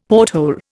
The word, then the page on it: porthole: Indian